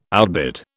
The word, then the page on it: outbid: British English